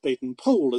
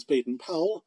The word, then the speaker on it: Baden-Powell: In the north, northwest of the Transvaal, close to British territory, there was the town of Mafeking, famously defended by Colonel Robert Baden-Powell, as he pronounced it, but the world knows him as Baden-Powell.